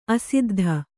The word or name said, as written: ಅಸಿದ್ಧಿ